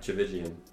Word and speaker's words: Tchividjian: T-C-H. – How do you say that? – Tchividjian.